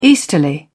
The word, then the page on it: easterly: British English